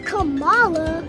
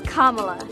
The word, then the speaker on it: Kamala: It's not Kamala. It's not Kamala. It's Kamala. Kamala Harris.